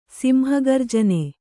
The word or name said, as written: ಸಿಂಹಗರ್ಜನೆ